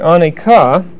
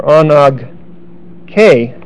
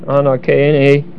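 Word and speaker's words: anag k (ἀνάγκη): The word necessity there is the word aneka, or anag k is the right pronunciation. Anag k, A-N-A-G-K-E.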